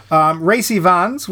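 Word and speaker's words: Rhys Ifans: Racy Vans, which is exactly how it says it's pronounced on Wikipedia.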